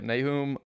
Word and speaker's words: Nahum: You're going to have to help me on Nahum. – A couple. Yeah, Nahum.